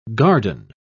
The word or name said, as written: garden